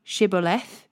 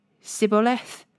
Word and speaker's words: Shibboleth: If the man said, "No, I'm not," they would tell him to Shibboleth. If he was from Ephraim, he would say Sibboleth, because people from Ephraim cannot pronounce the word correctly.